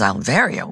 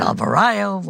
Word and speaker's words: Alverio: He says, Alverio, what is that? And I never liked it anyway because everybody always mispronounced it, Alvario, whatever.